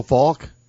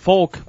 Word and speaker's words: Folk: By the way, if you watch the game, F-O-L-K, is that now pronounced Falk, like Marshall Falk? Because that's what Jim Nance called him the entire night. – I've always heard it Falk. – I always thought it was Falk, too.